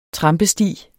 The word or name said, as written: trampesti